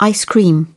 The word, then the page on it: ice cream: British English